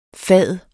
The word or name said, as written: fad